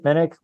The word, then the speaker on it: Minnich: I can be found on Twitter at Chip Minnick. Last name is spelled M-I-N-N-I-C-H.